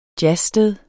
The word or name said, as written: jazzsted